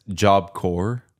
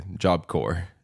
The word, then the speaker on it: Job Corps: Yeah, it's Job Corps. Non, le PS, c'est pas prononcé. Job Corps. – Ah. Oh. – C'est pas une corpse.